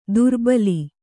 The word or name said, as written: ದೋರ್ಬಲಿ